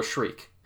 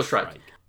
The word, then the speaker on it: Shrike: So this is Killer Shriek, by the way, and I will say, or Shrike? – How do you say Shrike, that? Yeah. – Yeah, Killer Shrike.